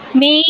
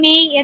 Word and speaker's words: Mey: Okay, for me, you can reach me at my Facebook page, May H. Espinol. Yung May, it's spelled M-E-Y. So, May H.